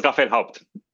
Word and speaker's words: Raphaël Haupt: I say that correctly? – Good enough. It's Raphael Haupt.